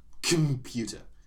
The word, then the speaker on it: computer: Also, when we think about computer, for example, the second letter or the first syllable. – So the O. – Yeah. – Yeah. – It's almost like there is no vowel sound.